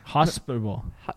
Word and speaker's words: hospitable: And finally, they are one of the more hospital signs. – What? – Am I saying that right? Hospital. – Okay. – Hospitable? – Hospitable. – No, H-O-S-P-I. – Yeah. – Table. Hospital. Hospitable.